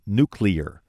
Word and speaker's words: nuclear: Well, last night, Sarah Palin pronounced it nuclear. In fact, I was looking at her teleprompter. That was the one word that was spelled out phonetically, N-E-W-C-L-E-A-R.